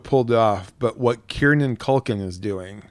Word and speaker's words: Kieran Culkin: It's Kieran Culkin. – Oh my God. – I'm telling you, there's no second N in there.